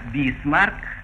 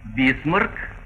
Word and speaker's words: Бисмарк: Так, «бисмарк» — это произношение по-иностранному, а по-русски «бисмарк» как «насморк».